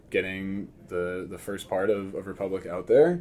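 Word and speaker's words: République: And I think you just pronounce it Republic. But in my brain, I'm like, a republique.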